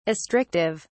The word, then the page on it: astrictive: British English